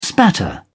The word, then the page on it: spatter: British English